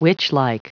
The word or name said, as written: witchlike